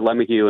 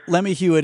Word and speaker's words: LeMahieu: Relatives who pronounce it Lemahieu. So it's a family dispute, but I prefer Lemahieu, and a lot of my close relatives do as well. – All right. Well, Lemahieu it is.